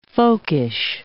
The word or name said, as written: folkish